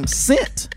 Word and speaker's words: Sint: Saint Nick's name became Sint, if I'm pronouncing that correct, S-I-N-T, Nicholas, in Dutch, or Sinterklaas for short, which is only a hop, skip, and a jump to Santa Claus.